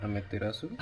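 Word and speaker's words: Amaterasu: Pero lo que no cuadra es que la versión oficial es que Himu es hijo de Ameterasu. Ame, no, siempre lo digo mal. Ameterasu.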